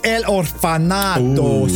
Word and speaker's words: El Orfanato: Euh, on regarde El Orfanato.